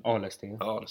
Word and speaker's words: Arlesten: Ja, och sen också Ludvig Allesten. Som är sjuk, Arlesten. – Ja, bra uttalat.